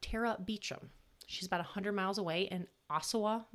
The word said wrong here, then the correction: Owosso: Michigan. That's not right. Owosso. – Yeah, I like that. – I don't know the pronunciation. It's O-W-O-S-S-O. Owosso? – Sure. – Owosso. We're going to go with Owosso, Michigan.